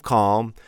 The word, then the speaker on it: calm: C-A-L-M. Interestingly, we pronounce the L here. Of course we do, right? Calm. But that's not like the word calf. How do you spell the word calf? Because the word calf has the silent L. C-A-L-F. Calf, we don't pronounce the L. We pronounce the L in calm.